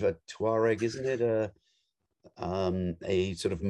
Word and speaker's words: Touareg: Well, you can pronounce it Touareg, or, you know, if you're from the American South, Targ.